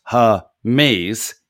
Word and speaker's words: Hermes: He says, Hermes, pronounced Hermes, is the Greek messenger god.